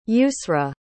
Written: yusra

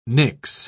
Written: nix

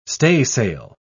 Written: staysail

